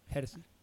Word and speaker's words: Gersey: She doesn't say Jersey. – No. Gersey.